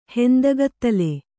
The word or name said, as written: ಹೆಂದಗತ್ತಲೆ